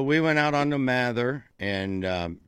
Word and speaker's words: Mather: Johnny and I went up on the, how do they pronounce the ship in Cleveland? The Walther? Mather? – Mather.